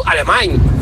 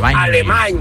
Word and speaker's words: Alemany: ¿Le podéis decir a vuestros colaboradores y tertulianos que no se dice Mateo Alemany? Se dice Mateo Alemany, como si acabaras con ñ. Mateo Alemany.